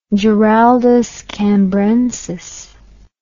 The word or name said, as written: Giraldus Cambrensis